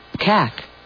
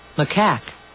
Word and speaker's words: macaque: Cack. – I don't understand why she insists on just playing the second syllable here. That's really not me doing this. – Macaque. – All right, there you go.